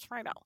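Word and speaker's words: Friedle: That's how he pronounced it on his podcast. So okay, I always thought it was Friedel, so I guess it's Friedel.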